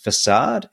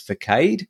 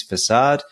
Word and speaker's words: facade: One thing to note: it, the the term is not um, it's pronounced facade. It's not pronounced as sometimes I've heard over the years, Darren, as facade. That's not how to say it. So it is facade.